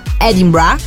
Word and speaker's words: Edinburgh: Quindi a distanza di non so quanti anni siano passati dalla mia terza superiore, molti anni, ancora oggi mi ricordo che Edimburgo in inglese si pronuncia Edinburgh perché è testa nel reggiseno, pensa.